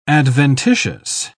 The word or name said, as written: adventitious